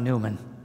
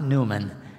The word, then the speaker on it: Neumann: The other one that we celebrate today is St. John Neumann. Now, if you're German, you know I'm pronouncing the name wrong, because it's not Neumann, it's supposed to be Neumann.